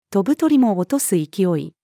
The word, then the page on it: 飛ぶ鳥も落とす勢い: female